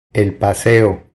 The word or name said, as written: El paseo